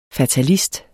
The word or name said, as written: fatalist